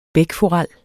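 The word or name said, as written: bækforel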